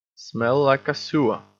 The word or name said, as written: smell like a sewer